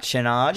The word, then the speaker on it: Chenard: When we're hard cut to Dr. I call him Chenard. How do you say it? Channing? Chenard? – Chenard. – Dr. Chenard. I'll just call him Chenard.